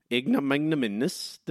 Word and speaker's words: ignominious: Ignominious defeat. That's how you say it, Matt.